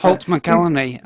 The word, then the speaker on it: McCallany: I was going to say, as a Fincher fan as well, there's the kind of the loveliness of having Holt McCallany. Well, I can never say his name right. – Holt McCallany.